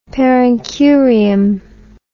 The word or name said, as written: per incuriam